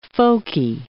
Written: folkie

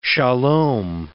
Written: shalom

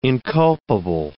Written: inculpable

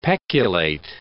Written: peculate

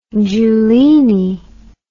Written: giulini

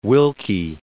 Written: Willkie

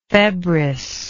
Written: febris

